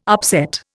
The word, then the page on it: upset: Indian